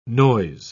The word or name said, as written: noise